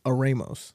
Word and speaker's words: Eremos: The funny thing is, I actually that is actually a mispronunciation. How do you actually say it? It technically is Eremos.